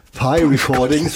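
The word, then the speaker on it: Pi Recordings: Ich habe mich eben versucht zu recherchieren, wie das P-Recordings auf Englisch ausgesprochen wird. Und es ist tatsächlich so, wie der, wie heißt das, der Kuchen? – Der Kuchen. – Der Kuchen, also es ist Pi-Recordings.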